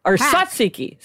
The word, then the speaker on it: tzatziki: Yeah, tzatziki.